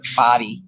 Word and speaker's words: Bode: He pronounced it like body. Okay, it's Peter Body. – Oh, you're being corrected. – I am corrected. I like that. I don't want to mispronounce his name. – Thanks, okay, Joe. – So Peter Body, not Bote.